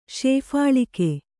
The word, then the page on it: ಶೇಫಾಳಿಕೆ: kannada